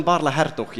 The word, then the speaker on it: Baarle-Hertog: Baarle-Nassau and Baarle-Hertog, yes.